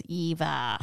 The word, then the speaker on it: EVE: And we find out her name is Eve, which I think in the script is Eve, E-V-E. – Yeah. – But as they pronounce it throughout as Eva. – Eva.